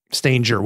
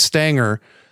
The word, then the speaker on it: Stanger: So my family says Stanger with a soft G. That's what I grew up saying. And then when I got into school, everybody would just pronounce it as Stanger.